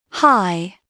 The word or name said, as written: high